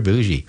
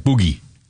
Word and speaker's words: bougie: Ooh, you're bougie. Spelt B-O-U-G-I-E, not J. It looks like it's spelled like boogie. It's bougie. Oh, how bougie of you.